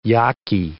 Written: Yaqui

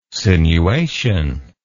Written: sinuation

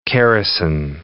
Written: Keresan

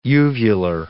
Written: uvular

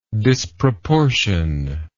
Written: disproportioned

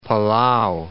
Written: Palau

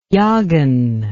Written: Yahgan

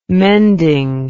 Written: mending

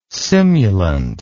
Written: simulant

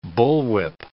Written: bullwhip

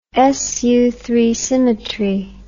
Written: Sutherland Falls

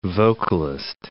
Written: vocalist

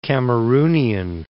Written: Cameroonian

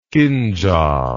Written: kindjal

